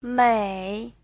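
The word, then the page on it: 美: mei3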